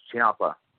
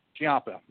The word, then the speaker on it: Ciampa: It's Ciampa. – Ciampa, yeah.